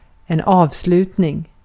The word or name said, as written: avslutning